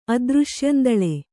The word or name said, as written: ಅದೃಶ್ಯಂದಳೆ